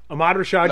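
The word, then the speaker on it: Ahmad Rashad: But did they really have a better '90s than Amara Rashad? – Ahmad Rashad no, couldn't pronounce Ahmad Dwayne was...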